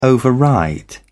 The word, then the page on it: override: British English